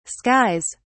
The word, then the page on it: skies: British English